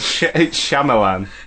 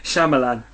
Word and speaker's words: Shyamalan: It's Shyamalan. – Shyamalan.